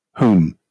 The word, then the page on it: whom: British English